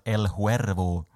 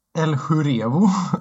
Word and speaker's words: El Huervo: Uh, gamers känner du kanske bättre under artistnamnet El Huervo. Åh, oh, tack för att du kunde uttala det rätt. Wow. Vad brukar folk säga? El Jurevo. Hurevo?